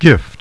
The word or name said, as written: gift